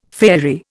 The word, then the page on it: faerie: Indian